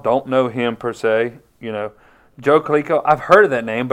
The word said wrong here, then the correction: Klecko: Joe Klecko.